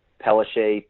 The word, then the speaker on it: Pellissier: If you can spell my last name, it's at Mike, M-I-K-E, Pelisse, P-E-L-L-I-S-S-I-E-R.